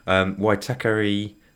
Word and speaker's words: Waitakere: And it's set in the, let's see if I can get this pronunciation correct, Waitakere Range.